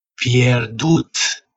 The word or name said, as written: pierdut